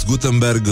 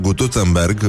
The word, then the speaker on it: Gutenberg: Uh, nu în ultimul rând, uh, Johannes Gutenberg uh, este inventatorul, probabil vă aduceți aminte, uh, și nu se pronunță Gutenberg, uh, cum a sugerat pe vremuri uh, Academia Casavencu. Uh, Este Gutenberg și nu Gutenberg, da?